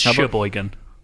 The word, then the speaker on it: Cheboygan: Takemenon, Cheboygan, Charlevoix. – Oh, well, you've already got one wrong. It's Sheboygan.